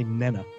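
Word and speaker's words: Pnenah: It's P-N-E-N-A-H. Would that just be Nena? – P-N-E-N-A-H. A-H. – I'm going to say Nena. – Yeah, probably, because, you know, like a pneumatic tool, you don't pronounce the P.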